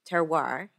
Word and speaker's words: terroir: So I've heard about your symposium, terroir.